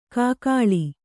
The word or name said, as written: ಕೂಗಳಿ